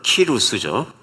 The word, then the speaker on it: 키루스: 예, 고레스는 뭐 히브리 발음으로 고레스입니다마는 그 원래 발음으로는 키루스죠.